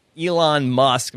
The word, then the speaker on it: Elon Musk: I did recently read an article where Elon Musk, am I pronouncing that right? – Yeah.